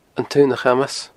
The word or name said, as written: An Taonachamas